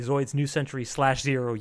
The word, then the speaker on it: Zoids New Century /0: Because we looked at Zoids New Century, originally Zoids New Century /0. Yes, you pronounce the slash. It's not a Hunter Hunter deal.